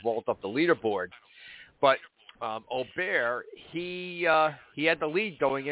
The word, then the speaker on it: Åberg: And in English terms, it's spelled A-B-E-R-G. Åberg is the way we Americans like to pronounce it, but it's called he's pronounced Åberg.